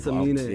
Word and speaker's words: Semine: We are we are with um, Elijah Simini. Did I said that right? – Yeah, I hope. Samine. yeah, Samine.